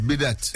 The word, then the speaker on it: bidet: It's bidet. – It's bidet. It's French.